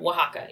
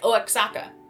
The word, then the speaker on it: Oaxaca: Just, Oaxaca, yeah. Because it looks like Oaxaca, if you're, you know, English.